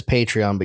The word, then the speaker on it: Patreon: Well, the site is Patreon, but you're a patron. – So you're a patron of Patreon.